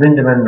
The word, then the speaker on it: Lindeman: We've got a website called Lindeman Reports. My name is spelled L-I-N-D-E-M-A-N.